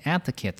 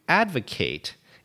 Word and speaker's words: advocate: Well, let's start by examining the verb form to advocate and pay attention to that subtle difference in pronunciation is to publicly support a cause. And often it means to put a lot of energy into the promotion of that cause. So, an advocate, noun form, and also notice the pronunciation difference, is a person who advocates for something.